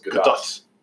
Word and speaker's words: Gadot: Is that how you say it? Gadot? – I believe it's Gadot. – I thought it was Gadot, but somebody from Israel told me it was Gadot.